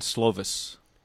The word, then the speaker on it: Slovis: Slovan? – Keaton Slovis.